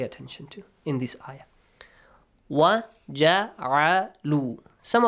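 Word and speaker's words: Wa (و): Make sure you don't say va. Va ja'alu, we don't have. In Persian, you might say va. Yes? – Yes. – In English, you might say v. You might have this sound with the letter V. But in Arabic, you don't have it. Wa.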